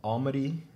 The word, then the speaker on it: Almaty: You're an international student from Almaty, and that's in Kazakhstan.